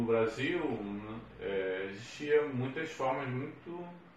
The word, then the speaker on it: Brasil: A palavra Brasil é bem representativo disso, quer dizer, se fala com som de Z, mas se escreve com, com S.